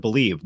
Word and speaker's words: BLEAV: Make sure to use the promo code BELIEVE. Now that's spelled BLEAV. BELIEVE to receive your rewards.